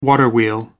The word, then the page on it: waterwheel: American English